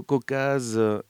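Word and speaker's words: Køcaze: Et ça s'écrit avec un O à la suédoise, le O barré, K-O-C-A-Z-E, Caucase, qui a sorti au milieu de l'été, en juillet, un album qui s'appelle D'or et de sang.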